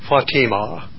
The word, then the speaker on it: Fatima: Yet his favorite daughter, good to hear, the Arabic pronunciation is Fatima.